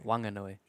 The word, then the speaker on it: Whanganui: Whanganui. – We'll play the proper pronunciation again. – Whanganui. – That is how you say it.